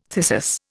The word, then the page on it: thyrsus: Indian